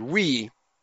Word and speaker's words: oui: By the way, I made a mistake last time in saying that the French word I-O-U, it should be O-U-I. I mistakenly announced that. It's O-U-I. The French word we is O-U-I with the I at the end. And that's pronounced, the O and U are pronounced like a W, but it's three vowels, O-U-I.